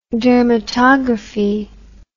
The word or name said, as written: dermatography